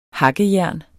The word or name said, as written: hakkejern